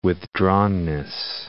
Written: withdrawnness

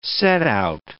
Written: setout

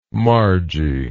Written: margie

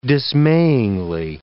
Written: dismayingly

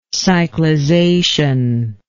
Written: cyclization

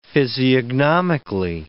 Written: physiognomically

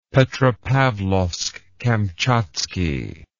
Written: Petropavlovsk Kamchatski

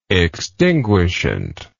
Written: extinguishant

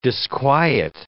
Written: disquiet

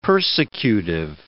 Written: persecutive

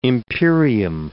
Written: imperium

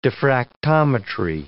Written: diffractometry

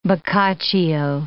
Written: bocaccio

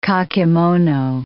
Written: kakemono

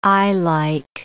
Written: eyelike

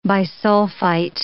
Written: bisulfite

